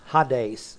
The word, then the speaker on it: Hades: Hades is the way it's actually pronounced.